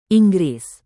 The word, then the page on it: inggris: indonesian